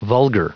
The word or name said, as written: vulgar